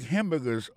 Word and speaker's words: hamburger: N'a pas remarqué que dans le mot hamburger, ça a comme d'habitude, il y a trois syllabes là-dedans. Quand on appuie et puis plus ça, c'est sous premier syllabe là.